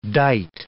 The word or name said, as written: dight